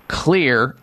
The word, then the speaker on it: Xlear: healthy natural nasal sprays called Clear, X-L-E-A-R, nasal spray. That's what you need to get, Clear, and in this case, in your case, the rescue version of Clear, and Clear is spelled with an X, X-L-E-A-R, rescue nasal spray.